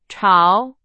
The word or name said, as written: 潮